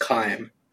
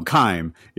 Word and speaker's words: Kaim: And in the fifth ending, basically, the game's protagonists, Akame and Angelus, traveled across a dimensional boundary to fight a monstrous beast. – Oh, quick note, his name is pronounced Kaim. – Oh, Kaim.